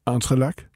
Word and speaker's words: entrelac: Du begyndte at strikke, øh, skriver du, entrelac. – Ja. Er det rigtigt udtalt? Ja.